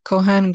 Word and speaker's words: Conan: My song is called The Story by Kohan Gray. Kohan is spelled C-O-N-A-N, Gray, G-R-A-Y.